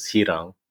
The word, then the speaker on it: Hirang: Well, so, I mean, I know how to pronounce my last name in like Tagalog, which is Hirang. – Hirang. – But then my first name is spelled R-Y-N-E, which is, I mean, there's other people that have that spelling I've seen before.